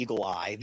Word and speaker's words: Eagle AI: But now Eagle Eye, it's pronounced the Eagle Eye. This is created by Cleta Mitchell, Trump's lawyer, who was part of the on those calls to try to get the Secretary of State of Georgia to reverse what happened. And so now she's brought it back. Eagle Eye, it's Eagle AI is how it's spelled, because they're using artificial intelligence to challenge even more voters.